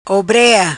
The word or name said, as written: Obrea